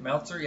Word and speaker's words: Meltzer: I mean, Brad Meltzer, how do you say his name again? Meltzer, yeah. He really knows the history of DC Universe and what the fans dig, especially long-term fans.